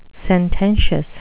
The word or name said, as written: sententious